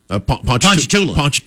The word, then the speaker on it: Ponchatoula: Ponchatoula. – Closer to Ponchatoula for the two of them.